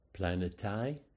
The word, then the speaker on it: planetai: It's planetai asteres. Well, that planetai, that sounds an awfully lot like planet, and it is. Planets were given that name because they wandered.